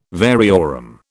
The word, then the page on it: variorum: British English